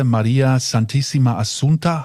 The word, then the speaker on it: Maria Santissima Assunta: Vermutlich schleifst du mich gleich zum Wahrzeichen der Stadt, der Kathedrale Maria Santissima Assunta. Habe ich das richtig gesagt? – Ja, das ist Monreale.